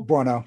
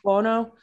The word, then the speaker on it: Buono: But maybe Cara uh what, how do you say her last name? Buono. – Buono, yeah. Buono.